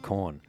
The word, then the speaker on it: Quorn: Brooke went on to explain that in some parts of Europe, they eat a lot of corn. That's spelled Q-U-O-R-N.